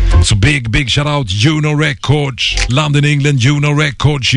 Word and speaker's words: Juno: And they are Uno Records UK, Uno Records in London, England. And Uno is spelled J-U-N-O, just like it sounds.